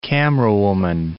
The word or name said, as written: camerawoman